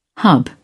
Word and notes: hub: British English

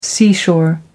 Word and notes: seashore: American English

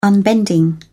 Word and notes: unbending: British English

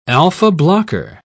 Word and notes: alpha-blocker: British English

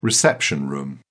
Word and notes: reception room: British English

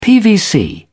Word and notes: pvc: British English

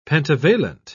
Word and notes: pentavalent: British English

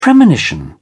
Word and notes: premonition: British English

